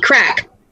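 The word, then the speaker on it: craic: The crack in the can it be spelled like the Gaelic word crack? C-R-A-C-R-A-I-C, crack. It means speaking.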